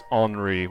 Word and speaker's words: Henri: He, he, Hi, I'm that's his name is Henri, which is spelled kind of like Henry, but.